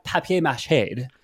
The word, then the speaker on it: papier mâché: But I have papier mâché.